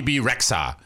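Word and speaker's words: Bebe Rexha: Is it BB Rexha? – BB Rexha.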